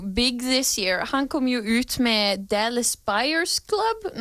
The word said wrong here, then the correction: Dallas Buyers Club: Nei, nå uttalte jeg det feil. Jo, Dallas Buyers Club, og han er med i The Wolf of Wall Street med Leonardo DiCaprio, ja, som kom ut senere.